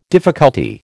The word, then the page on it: difficulty: American English